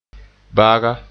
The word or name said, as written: baga